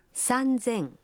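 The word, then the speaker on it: Санзен (三千): Но и здесь произношение меняется. 3000 будет звучать как... – Санзен. – 8000. – Хассен.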